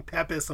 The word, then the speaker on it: Pepys: It's Samuel is the first name, last name, P-E-P-Y-S, and that's pronounced Pepys.